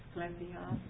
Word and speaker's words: Asclepios: Secondly, in that city was a temple of, how do you say that? – Asclepios. – It's better. Asclepios. Okay, I'm close.